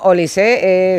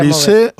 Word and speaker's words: Olise: Hombre, o sea, ahora mismo Olise es, vamos, yo no sé si el futbolista de moda total.